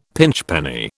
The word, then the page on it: pinchpenny: British English